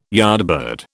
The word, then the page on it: yardbird: British English